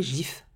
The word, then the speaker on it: GIF: Tout comme le dictionnaire anglais Oxford qui accepte les deux prononciations, mais, et je cite, à tort, c'est un G doux, prononcé GIF.